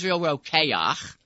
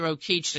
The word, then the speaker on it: Rokeach: I think they pronounce it Rokeach, the company now. – Rokeach, but in Europe it was Rokeach.